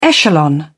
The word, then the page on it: echelon: British English